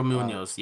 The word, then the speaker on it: Munhoz: Munhoz? – Pedro Munhoz, yeah.